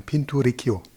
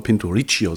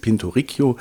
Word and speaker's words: Pinturicchio: Pinturicchio. – Genau, italienischer Maler, du hast es schon erwähnt, mit 2 C und ich will immer Pinturicchio sagen, aber es ist Pinturicchio und wir sprechen heute über das Bildnis eines Knaben, das hängt in der Dresdner Gemäldegalerie Alte Meister.